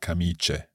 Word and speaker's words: camicie: So it's spelled camicie with C-I-A. – Right. But just to be absolutely clear, we pronounce that camice. – Camice. – Yeah. – Sì. – So there's no sort of stress on that I there. C-A-M-I-C-I-E. But that I is simply there making the C soft, although the E would do the same job.